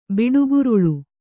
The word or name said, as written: ಬಿಡುಗುರುಳು